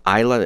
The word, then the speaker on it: Iyla: This is really beautiful soul pop and sort of R&B EP from a band called Isla. It's spelled I-Y-L-A, and the EP is called Other Ways to Vent.